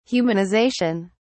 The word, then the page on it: humanisation: British English